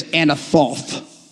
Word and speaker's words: Anathoth: Say Anathoth. No, you got to say it like this, Anathoth.